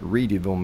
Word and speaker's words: Readyville: But I'd be remiss not to say, when we're talking about the mill, we're talking about Reedyville Mill, mm-hmm. which folks can Google, but it's spelled like Readyville, as you know, Truman, Readyville.